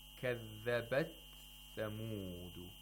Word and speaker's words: كذبت ثمود: Okay, another tajweed point is in the word kathabat thamudu. Kathabat thamudu. It's a little difficult. We have to pronounce the ta, and right after it, we have to pronounce the tha. And we have to make sure that the ta is heard clearly. – Without qalqala.